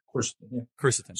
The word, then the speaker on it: quercetin: Talk a little bit about, I butcher the pronunciation. Hercetin, is that right? Quercetin? Hercetin? – Yeah, quercetin.